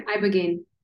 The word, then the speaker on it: Ibogaine: So we're going to talk about a few things, but specifically Ibogaine. Is that how you say it? – Yeah, Ibogaine.